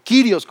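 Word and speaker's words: kirios (κύριος): Y la palabra, la, palabra Señor es la palabra curios o kirios como lo, como lo pronuncias en griego, pero es, es con S mayúscula porque habla de una autoridad superior.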